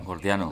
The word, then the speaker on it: Gordiano: al nudo Jordiano, ¿lo he dicho bien? Jordiano. – Gordiano. Sí, Gordiano, eso.